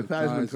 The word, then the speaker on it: Theismann: Joe Theismann changed his name from Theismann to Theismann to rhyme with Heisman.